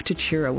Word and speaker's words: Tatura: I just heard the mayor and a couple of other people say Tatura. Emphasis on the tat. I emphasise chura. So am I doing it wrong?